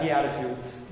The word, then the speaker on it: beatitudes: It says, ministers to the great multitude, and the beatitudes that he does afterwards. I don't know if I pronounced that correctly. – Yeah, beatitudes.